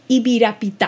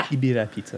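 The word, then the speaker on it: Ibirapitá: Ibirapita, okay. – Ibirapita. – It's kind of an awkward one to say, I realise. But yeah, it's got like the accent on the A on the last syllable.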